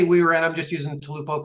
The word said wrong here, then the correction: Tupelo: because, and I, did I pronounce the name right? – Tupelo. – Tupelo. I knew I had it off a little.